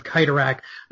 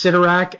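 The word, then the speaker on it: Cyttorak: Yes, and thank you for pronouncing it with a hard C, because we all know Stan Lee likes his alliteration, so it would be Crimson Bands of Kytorak, and I've heard it pronounced Sidorak, and it just makes me cringe. – Well, really, when you have the C-Y together, it is more of a hard C sound.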